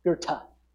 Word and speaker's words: Goethe: So a German philosopher named Goethe, that's how you say it, Goethe, said this: "Things which matter most must never be at the mercy of things which matter least."